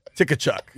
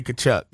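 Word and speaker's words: Tkachuk: Silent T, dog. – Yeah. – It's probably like Slavic. I don't know the country of origin, as the spelling bee kids would say. – They just thought, we're going to put a silent T right here, and it's just going to say Kachuk.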